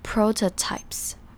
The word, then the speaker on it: prototypes: PRO 这边第一个 O 是念成长母音 O O 的发音，而第二个 O T O 的那个 O 则是念成 a 的念法，所以是 prototypes。